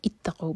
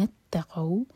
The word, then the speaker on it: اتقوا: للذین اتقوا, یہاں پہ یہ حمزۃ الوصل ہے بیچ میں, ملائیں گے تو اس کو پرونانس نہیں کیا جائے گا, اگر الگ پڑھیں گے تو اتقوا بنے گا. یہ ملا کر پڑھیں گے.